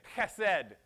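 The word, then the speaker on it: chesed (חסד): It's the word chesed. I'm going to teach you Hebrew. Say chesed. If you didn't spit on somebody, you didn't say it right.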